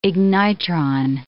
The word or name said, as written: ignitron